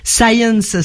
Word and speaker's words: Sayens: Science, ça s'écrit, ça se dit comme la science en anglais, mais ça s'écrit S-A-Y-E-N-S.